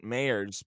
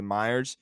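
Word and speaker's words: Mayer: Yeah, so and it's named after a It's based off Louis B. Mayers. Mm-hmm. That's how he pronounced it, Mayers. But I just phonetically like to say Myers.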